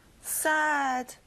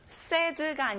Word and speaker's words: sad: 슬픈, sad. 여러분, sad가 아니라 입을 벌려서 sad.